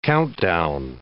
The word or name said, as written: countdown